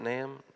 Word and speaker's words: Nahum: It's right before Naaman, I think. Is that how you say it? Nahum. I know there's different ways of saying it.